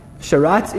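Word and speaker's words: Sharatz (שרץ): Sharatz in English? S-H-A-R-T-Z. And if you're from the States, then you would spell it T-Z. S-H-A-R-A-T-Z, yeah, right on. If it's easier in Hebrew, it's just three letters, Sheen, Resh, and Zadi.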